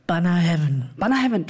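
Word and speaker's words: Bunnahabhain: Banner Heaven, du hast das richtig ausgesprochen.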